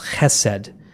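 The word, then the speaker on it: chesed (חסד): There's a very important Hebrew word in the Old Testament that's pronounced chesed, and you'll find it translated differently in different translations.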